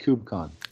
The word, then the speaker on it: KubeCon: Is that really how they say it? KubeCon? KubeCon? I sound like my dad just mispronouncing things on purpose. – I mostly have heard KubeCon.